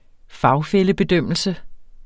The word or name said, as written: fagfællebedømmelse